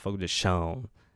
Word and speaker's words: Fogo de Chão: It's called Fogo de Chão, or if for a more Americanized accent, Fogo de Chão, because there is an accent on the A over there, and it's hard for a non-Brazilian to get that right, but it's Fogo de Chão.